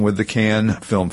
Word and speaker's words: Cannes: Am I saying that right? – I think it's Cannes. – Okay. Is it Cannes? C-A-N? – I've heard it both ways.